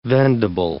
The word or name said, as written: vendible